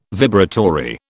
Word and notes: vibratory: British English

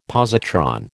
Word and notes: positron: American English

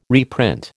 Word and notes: reprint: American English

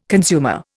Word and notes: consumer: Indian

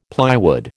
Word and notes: plywood: American English

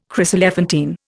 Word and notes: chryselephantine: Indian

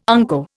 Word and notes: unco: Indian

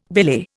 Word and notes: belay: Indian

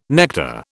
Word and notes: nectar: British English

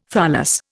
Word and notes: tholos: Indian

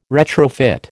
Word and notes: retrofit: American English

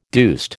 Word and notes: deuced: American English

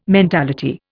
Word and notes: mentality: Indian

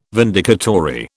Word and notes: vindicatory: British English

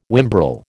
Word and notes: whimbrel: American English

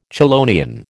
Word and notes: chelonian: American English

